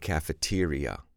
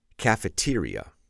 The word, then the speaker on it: cafeteria: In other words, we typically don't use the word cafeteria when we want to say coffee shop. Please repeat, cafeteria, cafeteria. Remember how to improve your pronunciation by focusing on where the stress goes on the syllable.